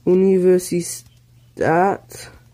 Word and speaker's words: Universität: Mein Mitbewohner, die Uni, how do I say that? Universe is at, oh yes, the A has the one thing on it. Universität in Berlin. There we go.